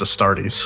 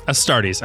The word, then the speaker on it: Astartes: And before all the nerds scream, at least to my knowledge, it's pronounced Astartes. – Astartes.